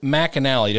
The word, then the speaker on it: McAnally: All right, joining us on the phone, Ben McAnally.